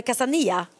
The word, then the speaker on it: Casanilla: Jag, jag väljer nog mellan Casa Dream, Casa Pina, eh, Casa Estrella Casanilla är också fint i och för sig, men då uttalas det Casania. För man säger ju inte eh, L i Spanien.